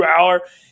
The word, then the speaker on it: Allar: know, I was saying Alar and I was wrong. it's Drew Allar.